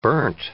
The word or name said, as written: burnt